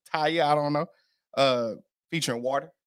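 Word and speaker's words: Tyla: Tyler.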